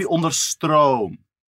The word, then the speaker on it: Onderstroom: it should be Onderstrom. – Onderstrom. – Like, oh! – Okay. – Onderstrom, yeah.